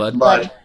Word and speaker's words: Lud: L-U-D? – Yeah. – Ludd.